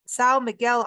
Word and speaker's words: São Miguel: But today she's going to be talking about São Miguel Island. Did I get that part right? – Yes.